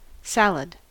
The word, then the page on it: salad: American English